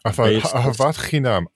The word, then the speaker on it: ahavat chinam: Ahavat chinam, right? – Ahavat chinam.